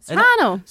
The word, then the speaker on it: Serrano: Is that how you pronounce that? Serrano? – Serrano. – Serrano, yeah. I've heard lots of different things.